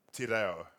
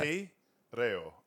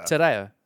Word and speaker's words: Te Reo: I don't want to be an asshole, but you just fucked up Te Reo. – How do you say it? How did I say it? – Te Reo. I mean, I'm not good either. – Te Reo. – Te Reo. – That's pretty good. – Yeah, you're getting better.